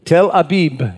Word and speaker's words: Tel Aviv: Tel Aviv. Tel Abib, it would be pronounced.